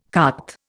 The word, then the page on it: carte: Indian